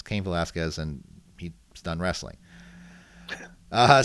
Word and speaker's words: Velasquez: Like um, how how do I pronounce it? Again? Cain Velasquez. – Velasquez. You Velasquez. Said right, yeah.